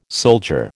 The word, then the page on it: soldier: American English